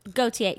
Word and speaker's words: Gauthier: Did I say that right? – Gauthier, yes.